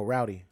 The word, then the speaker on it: Rowdy: Roddy Rebel. – Oh, Rowdy.